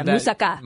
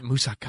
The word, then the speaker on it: moussaka: Moussaka. – Moussaka.